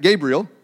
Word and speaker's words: Gabriel: There's uh, Gabriel. Am I saying that right? Gabriel, Sorry, my emphasis got on the wrong syllable there, uh, which means the great one, and then Lucifer, which means morning star.